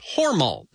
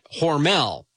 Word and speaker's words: Hormel: Yes, the company name is actually pronounced Hormel, not Hormel, but I digress.